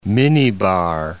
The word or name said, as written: minibar